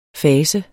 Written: fas

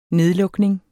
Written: nedlukning

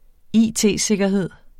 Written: it-sikkerhed

